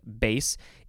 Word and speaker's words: bass: So this is the second form of the word that's spelled the same way, but it's pronounced bass.